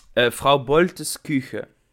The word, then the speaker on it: Kuchen: Vrouw Boltes Koegen. Koegen? Koegen? Zeg ik dat goed, Job? – vrouw ehm, Boltes Koegen. – Koegen. Of eigenlijk Kuchen. – Kuchen. Vrouw Boltes Kuchen.